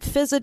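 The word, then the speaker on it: Fiza: I'm at Fizza Dasani on all platforms, Instagram, Twitter, Clubhouse.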